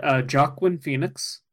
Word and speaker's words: Joaquin Phoenix: Yeah, starring everybody's favorite funny man, Joaquin Phoenix. – Yep, that's how you say it.